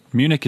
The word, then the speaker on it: Munich: Munich is good. – Munich. – The Germans call it München.